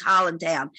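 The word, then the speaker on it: Highlandtown: It's spelled Highland Town, but it's Holland Town.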